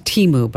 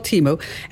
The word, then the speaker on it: Temu: It's pronounced Temu, by the way. – Okay. – That's all right. It's called Temu, and it does.